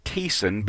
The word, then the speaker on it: Thiessen: It's actually called Thiessen, but it's spelled Thiessen, T-H-I-E-S-S-E-N.